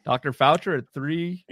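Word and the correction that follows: Faucher: Calvin Fauché, I should pronounce his name properly.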